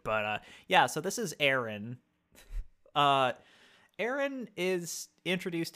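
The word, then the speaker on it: Aron: Yeah, with an A. So it's A-R-O-N, but I believe mm-hmm. it's pronounced Aaron.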